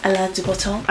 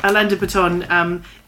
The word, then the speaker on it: Alain de Botton: Alain de Botton. – Alain de Botton.